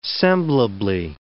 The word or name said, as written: semblably